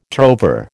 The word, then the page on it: trover: American English